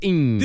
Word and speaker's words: Ng: NG, but pronounced ing.